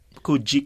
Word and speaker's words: Coogee: Kuji.